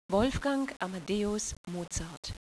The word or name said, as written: Mozart